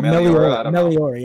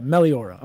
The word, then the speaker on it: Meliora: Or how do you pronounce that album? Is that it? – Yeah, but I don't know. Meliora. – Yeah, okay. Yeah, Meliora. Okay.